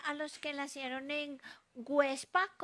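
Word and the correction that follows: Güepsa: Perdón, Huepsa. Websa, con diéresis.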